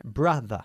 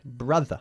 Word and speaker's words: brother: Brother. – Brother. – No brother. Brother. – Brother. – Como mother. Okay?